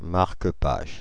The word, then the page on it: marque-page: [maʁ.kə paʒ]; Paris